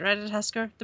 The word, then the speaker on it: Ratatoskr: Ratatoskr. – I think you said it right. Ratatoskr.